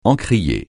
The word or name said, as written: encrier